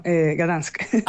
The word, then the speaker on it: Gdańsk: Yo a veces yo les comento como, ah, yo viví en Gdansk, y como que no entienden, a lo mejor lo pronuncio muy mal, y luego digo Danzig, y es como, ah, no, no, Gdansk. – Ah, claro. – Gdansk, creo que dicen.